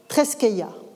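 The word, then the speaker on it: treskeia (θρησκεία): il va préciser que religio traduit le grec treskeia en prononciation érasmienne.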